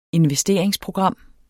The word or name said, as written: investeringsprogram